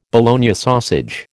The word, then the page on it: Bologna sausage: American English